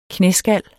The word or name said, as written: knæskal